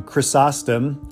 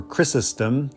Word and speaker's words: Chrysostom: And the reason he's called John Chrysostom, that was not the name he was born with, Chrysostom, or Chrysostom, depending on how you pronounce it, is it means golden mouth, to have a golden mouth.